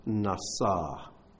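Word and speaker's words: Nasa (נסה): We learn to be supported by God through Nun Samech Hey. That spells Nasa. But that's how it's pronounced.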